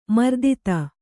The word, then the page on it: ಮೂರ್ತತೆ: mūrtate